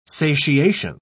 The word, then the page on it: satiation: British English